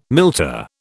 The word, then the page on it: milter: British English